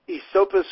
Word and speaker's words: Esopus: So correct my pronunciation on that creek. – Esopus Creek. And that trips up it trips up everyone who didn't grow up near it.